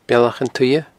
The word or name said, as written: Bealach an t-Suidhe